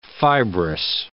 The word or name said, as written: fibrous